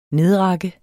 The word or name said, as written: nedrakke